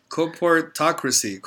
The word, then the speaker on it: Corporatocracy: Is it called Corpusori? Wait a minute. Hold on. What is it called? – I don't know. Let's check this out. – Corporatocracy.